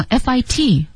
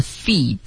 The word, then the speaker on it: fit: 如果这样的话，像 FIT，我是不是就可以干脆读成脚那个词 feet？ – No, you cannot. Fit 是一个大写的 AI 的这个音标发 A，然后脚丫子就是发 feet，是一个 E 的音。